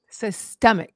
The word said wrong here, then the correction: Septemics: Is that my, am I saying it right? – S-E-P-T-E-M-I-C-S. Okay. Septemics.